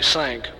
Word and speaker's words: cinq: What about the spelling, C-I-N-Q? – C-I-N-Q is French for five. It's pronounced actually sank, but we're Texans and that's the way we speak it too, sank.